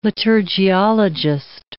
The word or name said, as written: liturgiologist